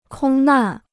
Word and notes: 空难: kōng nàn

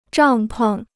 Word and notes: 帐篷: zhàng peng

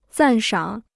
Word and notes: 赞赏: zàn shǎng

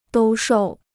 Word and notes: 兜售: dōu shòu

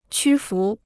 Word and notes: 屈服: qū fú

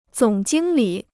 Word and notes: 总经理: zǒng jīng lǐ

